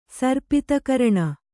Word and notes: ಸರ್ಪಿತಕರಣ: sarpita karaṇa

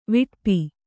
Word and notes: ವಿಟಪ: viṭapa

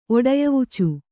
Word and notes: ಒಡಯವುಚು: Kannada

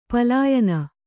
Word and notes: ಪಲಾಯನ: palāyana

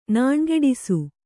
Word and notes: ನಾಣುಗೆಡಿಸು: nāṇugeḍisu

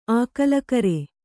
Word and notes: ಆಕಲಕರೆ: kannada

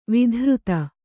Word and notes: ವಿತರ್ದಿ: vitardi

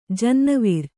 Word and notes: ಜನ್ನಿವಾರ: jannivāra

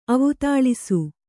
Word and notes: ಅವುತಾಳಿಸು: kannada

